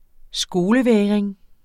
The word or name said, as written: skolevægring